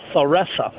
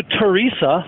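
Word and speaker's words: Theresa: I graduated from Lamira, 45 minutes north of Milwaukee, and town just south of there is called Thoresa, T-H. They actually pronounce the T-H. – Uh-huh. – And somebody I know was adamant that, we went to Teresa, like Mother Teresa, to Joel's Confections, which is a popular place in town.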